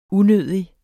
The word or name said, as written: unødig